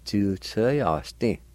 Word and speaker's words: tsulitsvyasdi: And in this word, the I with the L will be silent. You can pronounce the L with TSU like this. Tsul tsa ya sti. Tsul tsa ya sti.